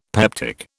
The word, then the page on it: peptic: British English